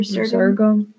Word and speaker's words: Resurgam: Resurgam.